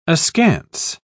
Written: askance

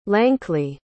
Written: lankly

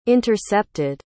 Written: intercepted

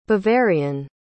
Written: bavarian

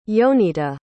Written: yonita

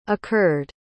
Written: occurred

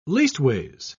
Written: leastways

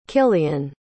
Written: kilian

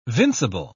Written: vincible

